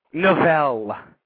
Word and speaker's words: Navelle: It's pronounced Navelle. – Navelle. – Yes. – Like Duval, but a little different.